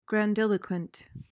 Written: grandiloquent

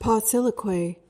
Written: pauciloquy

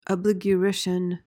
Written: abligurition

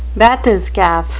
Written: bathyscaph

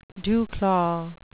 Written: dewclaw

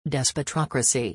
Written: despotocracy

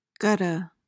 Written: gutta

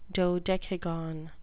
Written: dodecagon

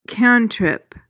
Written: cantrip

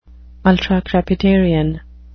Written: ultracrepidarian

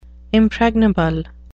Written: impregnable

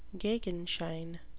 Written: gegenschein

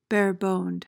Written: bareboned

